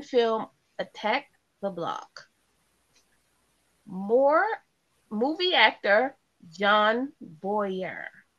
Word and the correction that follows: Boyega: Am I saying that right? Boyer. Wait, B O Y E B O Y E D what? – B O Y E G A Boyega. Boyega.